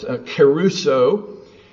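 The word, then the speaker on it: keruso (κηρύσσω): The word preach here is a Greek word that is pronounced keruso, and it means to proclaim publicly as a herald.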